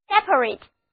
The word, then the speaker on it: separate: 注意，如果最后一个 A 字母从 A 的本字母音换为 A 的短音，单词读作 separate，就变成一个形容词，表示分离的、各自的、单独的。